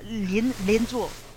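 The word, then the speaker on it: Lianzhu (连坐): So I'm going to pronounce this incorrectly, but Lianzhu? – Yeah, Lianzhu. – So, Lianzhu. – You're right. Yeah.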